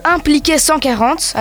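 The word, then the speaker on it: Impliqué 140: Quel est ce son qui va empêcher euh, nous les darons de devenir des vieux cons euh, C'est le son de impliquer 140. Alors écoutez bien, hein, ça s'écrit 1 P L I K E accent aigu espace 140.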